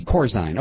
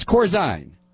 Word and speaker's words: Corzine: It's Corzine. Okay, I pronounce it Corzine. It's Corzine. Thanks, Kathy.